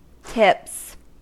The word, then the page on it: tips: American English